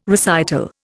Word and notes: recital: Indian

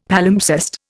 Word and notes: Palimpsest: Indian

